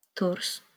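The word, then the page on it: Tours: Polish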